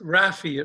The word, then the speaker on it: Raffi: Just Raffi. It rhymes with Daffy. – Raffi.